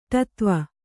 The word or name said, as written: ಟತ್ವ